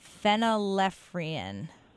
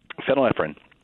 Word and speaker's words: phenylephrine: Phenylephrine. – Yep. – No. – Phenylephrine. – Phenylephrine.